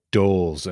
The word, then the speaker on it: Dols: Well, I have Doles, and so I get all kinds of dolls and all kinds of weird mispronunciations of my last name.